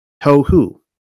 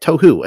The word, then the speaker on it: tohu: You pronounce it like this, tohu. Try it, tohu. Again, tohu. Practice makes perfect.